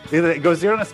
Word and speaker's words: Gojira: Gojira Special, and I think it's the Japanese pronunciation of Godzilla.